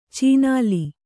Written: ಚಿನಾಲಿ

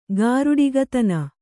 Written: ಗಾರುಡಿಗತನ

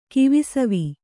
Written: ಕಿವಿಸವಿ

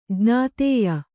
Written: ಜ್ಞಾತೇಯ

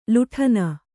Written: ಲಾಟನ

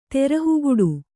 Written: ತೆರಹುಗುಡು

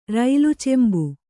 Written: ರೈಲುಚೊಂಬು

